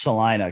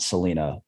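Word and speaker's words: Celina: It's pronounced Salina because I've made that mistake before and then he corrected me. So it's Grand Lake Salina, not Salina.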